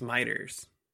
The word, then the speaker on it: Michter's: but I guess it's Michter's. Is that how you say it? – I think it's Michter's. – Michter's? – Yeah. – Michter's, a Louisville based distillery.